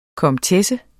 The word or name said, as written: komtesse